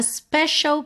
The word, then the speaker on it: special: A special place in my heart. Special. Também pintei de verde aqui para você ter atenção a essa pronúncia, principalmente essa letra C, que não soa C, e sim SH.